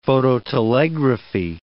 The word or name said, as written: phototelegraphy